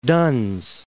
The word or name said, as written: Duns